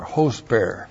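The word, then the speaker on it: hosper (ὥσπερ): Looks like that in the Greek, H long O S P E R, hosper.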